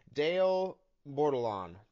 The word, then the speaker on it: Dale Bordelon: Today I'm with Dale Bordelon.